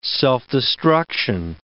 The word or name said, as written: self-destruction